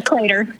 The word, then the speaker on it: Claytor: Claytor. Yeah. – Like, kind of like Gator Claytor.